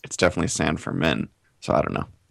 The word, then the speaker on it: San Fermin: But on the band's website, on the About page, it does say the name is pronounced San Fermin.